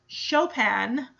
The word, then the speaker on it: Chopin: is actually pronounced Chopin. Any kid looks at C-H-O-P-I-N says is gonna think, if they don't know it, Chopin. Chopin. Like, chop and in are two separate words that are smushed together.